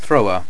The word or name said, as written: thrower